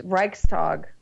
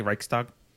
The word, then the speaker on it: Reichstag: Chavez says the pronunciation is Reichstag. – It's like hard K, Reichstag?